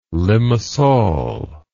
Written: limmasol

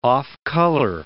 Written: off-color

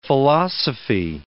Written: philosophy

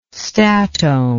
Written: statohm

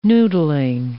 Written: noodling

